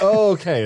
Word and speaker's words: okay: Like, See, it's not, okay, it's, okay. That's, I understand. – The emphasis is on the K, not on the O.